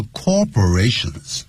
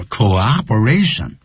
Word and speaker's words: cooperation: 我们当上飞机的时候， thank you for your cooperation，感谢您的合作。 cooperation is cooperation，所以一定要念成 co，再来一个 operation。可是我们乘务员呢或者一些呢就是机长呢常会念 thank you for your cooperation，不是 corporation，这叫 cooperation。如果你念成 corporation 代表公司了啊，那就是特别要记住。